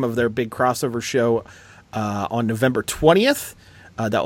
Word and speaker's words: X-over: and I think it's supposed to be pronounced like cross, but it's spelled X-over, like it's an X-Men series from the 90s.